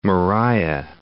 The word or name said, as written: Moriah